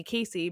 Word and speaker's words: Casey: I still have people who pronounce it Cassie. But when I was like really young, my mom told me the reason why she named me Casey, because everybody and their mother was naming their kid Katie in 1991.